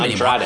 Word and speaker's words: Andrade: The first thing was there was no Andre. I can't say his name Andrade.